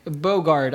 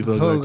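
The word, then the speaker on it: Bogarde: And Dick Bergdahl? – It is, let me get it. I can't really say his name. – It is Dick Borgund? – Can somebody get it? – Bogard. I'm so sorry. – Yeah, Dirk, it would be Bogard.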